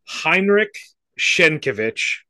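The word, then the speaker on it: Henryk Sienkiewicz: Heinrich Schenkevich.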